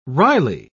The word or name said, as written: wryly